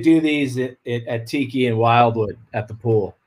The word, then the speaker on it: Wildwood: You don't pronounce the D really. It's like Wildwood.